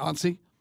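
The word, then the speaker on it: Onsi: Ansi? – Yes.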